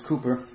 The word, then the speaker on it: Cowper: Spelt like Cowper, but actually is pronounced Cooper.